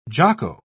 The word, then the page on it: jocko: British English